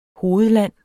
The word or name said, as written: hovedland